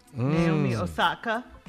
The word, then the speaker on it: Naomi Osaka: Naomi Osaka.